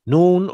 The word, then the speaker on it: Nun: I was doing some reading the other day, and Nun, am I saying it right? – Nun, or how you say Nu, the same Nu. The term, Nu or Nun.